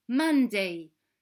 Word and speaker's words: Monday: Monday se prononce Monday, comme la fin de Superman.